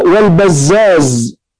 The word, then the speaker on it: البزاز: مثلا البزار بالراء المهملة والبزاز بالزاي المعجمة، البزار والبزاز هذه نسبة، هو ما اتفقت صورته في الخط اهو بزار وبزاز الاثنين متفقين في الخط ولكن اختلفت في النطق.